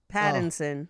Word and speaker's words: Pattinson: Paddington. – Pattinson. Oh, Pattinson. – Pattinson.